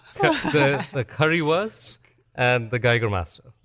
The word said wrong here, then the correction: Jägermeister: right? – Jägermeister. – Jägermeister, the right way to say it.